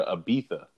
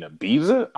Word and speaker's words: Ibiza: like they pronounce their Z's with a TH. Like they go to Ibiza. Like you mean Ibiza?